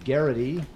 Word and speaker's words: Geraghty: This um, is about a guy played by Brian uh, Geraghty that's how you pronounce it.